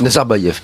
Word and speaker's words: Nazarbaïev: D'ailleurs, euh, c'est un cadeau qui a été fait par le président kazakh, le président Narzaïev, en 2009. Nazarbaïev pardon.